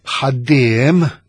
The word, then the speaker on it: ħaddiem: Jekk mill-banda l-oħra ngħid ħaddiem, ħaddiem, naf illi qed nitfa l-ħafsa tiegħi fuq it-tieni sillaba, jew nitfa hija twal, ħaddiem bħal baħħar, ħajjat.